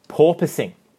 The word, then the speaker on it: porpoising: They have been dealing with a lot of, and correct me if I'm wrong in pronouncing this, porpoising.